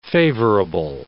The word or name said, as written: favorable